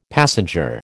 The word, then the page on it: passenger: American English